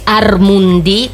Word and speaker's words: Armundì: E quindi sì, ci potete seguire, siamo sui social, basta che scriviate Armundì con l'accento finale sulla I, e trovate i riferimenti.